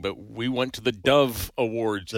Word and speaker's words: Dove: Dove, D-O-V-E, because two people thought I said Devil Awards for some reason.